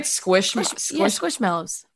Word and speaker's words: Squishmallows: Do you have a lot of squishmallows with you? – Ugh, only one. – Am I saying that right? – Squishmallows.